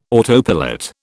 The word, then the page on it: autopilot: British English